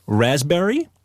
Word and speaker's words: raspberry: K-N-E-E-L, Neil. – Raspberry, answer. – Silent letters. – Yes, silent letters. Oh, yeah. Raspberry is the silent S. – P. – Or P. Sorry. – Silent P, right?